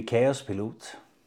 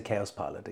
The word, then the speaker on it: Kaospilot: So in Danish it would be Kaospilot. Chaos Pilot. Yes, but in uh, in English we will say Kaospilot exactly.